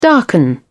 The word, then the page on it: darken: British English